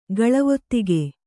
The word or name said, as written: ಗಳವೊತ್ತಿಗೆ